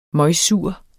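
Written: møgsur

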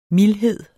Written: mildhed

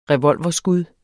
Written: revolverskud

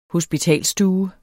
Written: hospitalsstue

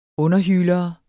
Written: underhylere

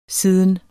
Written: siden

